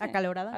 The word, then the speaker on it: acalorada: Todavía, toda cansada, con la acaloreada. Ah, ¿Cómo se dice? Acalorada. Acalorada, ajá. Em, y luego todavía, no, o sea, me hizo como que no puedo creer que seamos capaces de todo esto.